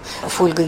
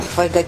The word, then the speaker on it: фольга: «Дождь» и «По средам» – все это, в общем, «Фольга» и «Кетчуп» – все это ударения, которые ушли, и, в общем, нечего об этом говорить, потому что язык меняется. И если современные грамотные носители языка, которых мы все готовы признать грамотными, говорят всегда «Фольга», «Кетчуп», «Йогурт» и там что еще, «Дождь» и «По средам», то, в общем, надо признать, что тут норма уже изменилась.